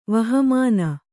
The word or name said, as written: ವಹಮಾನ